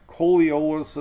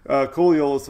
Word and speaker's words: Coriolis: And they have a Coriolis effect. I butchered that name. Coriolis effect.